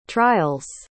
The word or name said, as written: trials